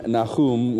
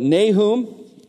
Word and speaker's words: Nahum: I'm going to pronounce it in the English pronunciation, the way that you're commonly familiar with it heard, rather than Nahum, which is the more of the Hebrew style.